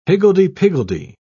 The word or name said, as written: higgledy-piggledy